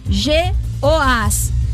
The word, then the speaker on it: Jeoás: J-E-O-A-S. É a pronúncia. Jeoás.